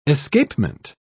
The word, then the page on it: escapement: British English